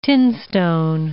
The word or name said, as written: tinstone